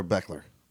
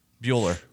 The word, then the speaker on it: Buehler: By the way, is it Walker, Buechler, or Beckler? – Bueller. – Bueller. – It's Bueller. – The CH is silent.